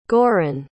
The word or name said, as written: giron